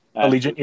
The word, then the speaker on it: Allegiant: So someone like, I don't know, Allegiant, I think that's how you pronounce it. – Allegiant, yeah.